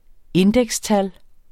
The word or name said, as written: indekstal